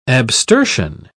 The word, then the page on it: abstersion: British English